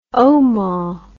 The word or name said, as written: omah